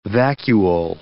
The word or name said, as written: vacuole